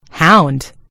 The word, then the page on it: hound: American English